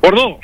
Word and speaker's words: Bordeaux: Bordo.